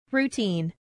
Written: routine

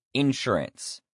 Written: insurance

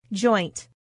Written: joint

